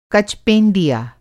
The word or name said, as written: कच-पेंदिया